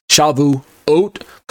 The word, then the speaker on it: Shavuot: Shavu plus the suffix ot, pronounced oat, not ot. Shavuot, coming from the root word shavua, like Mr. Richardson just mentioned.